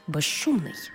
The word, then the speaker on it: безшумний: У словах безшовний і безшумний, свистячий з плюс шиплячий ш всередині слова разом вимовляємо як ж ши, безшовний, безшумний.